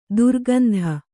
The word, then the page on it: ದರಿಕಂದ: dari kanda